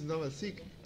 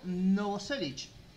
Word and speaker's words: Novoselic: Con el impronunciable, el Chris Novelsick Sick. – Sí, decime. O te digo. – Te digo mejor. – Pronunciado Novoselic. Tomá.